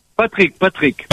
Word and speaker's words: Patrick: C-E-U-S, en quatre lettres, exactement. – Céus, Patrice, Patrick, hein, Patrice. – Patrick.